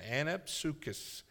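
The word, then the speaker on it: Anapsukis (ἀνάψυξις): Now, that word refreshing, anapsukis, now that Greek word is a long one for me, okay? I'm going to try it again. Anapsukis, properly, or here's how you say it, recovery of breath.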